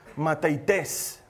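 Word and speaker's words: mateites (μαθητής): Esta palabra griega discípulo se pronuncia mateites.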